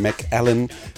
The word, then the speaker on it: Mc4len: Äh, man schreibt es mit einer 4 statt des A's, wenn ihr das suchen möchtet. MC4LEN, Mac Allen spricht man es aus.